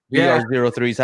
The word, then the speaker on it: BR03: I mean, you guys are selling like crazy on those Bro 3s. BR03s, I don't know the proper way to say it, but yeah. – Yeah, it was BR03 because in the French it doesn't sound the same, so we didn't find out first.